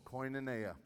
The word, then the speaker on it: koinonia: Koinonia.